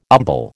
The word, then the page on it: umbel: American English